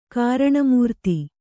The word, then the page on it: ಕರ್ಣಾಮೃತ: Kannada